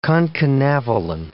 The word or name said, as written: concanavalin